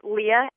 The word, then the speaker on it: Leah: Okay, Twitter is Leah, L-E-A-H, J as in Jocelyn, just the initial J, and then Mackenzie is my last name, and that's spelled M-A-C, capital K-E-N-Z-I-E.